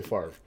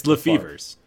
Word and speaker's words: Lefevre: It's spelled like Favre, so I'm going to say Favre. – It's Lefevers. It's not spelled like Favre. There's no A. It's Lefevre. Brett Favre. – And they constantly, whenever they reference their names, they also pronounce it Lefevers.